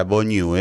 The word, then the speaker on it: Bonnieu: Así se pronuncia, Bonnieu, es francés el apellido.